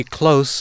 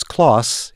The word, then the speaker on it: Kloss: Henry Close has died. I had always pronounced his name as Kloss. It's spelled K-L-O-S-S.